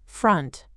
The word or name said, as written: front